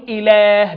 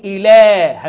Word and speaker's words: إله: إله الناس، خلي بالك من كلمة إله، هتلاقي مرسومة همزة لام هاء، وأنا بنطقها بقول إله، بمد اللام في ألف ملحقة في الضبط، في ألف قصيرة بعد اللام ملحقة في الضبط، هذه الألف تنطق وهذه الألف مقروءة، أقول إله، همد اللام أقول إيه؟